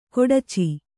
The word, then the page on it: ಖಡಚಿ: Kannada